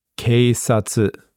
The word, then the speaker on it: keisatsu: You would say keisatsu or keisatsu. – Keisatsu. – Keisatsu. Or you would say deka. – Deka. – Which is like cop.